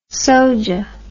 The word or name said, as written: soja